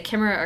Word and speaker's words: chimera: You can say chimera or chimera.